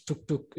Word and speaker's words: tuk-tuk: Yeah, we call it tuk. Yeah, it's tuk-tuk. You probably can pronounce it as tuk-tuk. – Oh, yeah. – It's tuk-tuk, yeah.